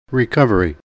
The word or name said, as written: recovery